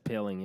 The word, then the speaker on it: Poehling: I'm very excited to see what Ryan Poehling, is that how you say it, Poehling? – Yeah, it's Poehling, yeah.